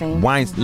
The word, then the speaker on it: Weinstein: Weinstein.